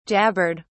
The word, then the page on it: jabbered: British English